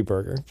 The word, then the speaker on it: Berger: It could be Berger,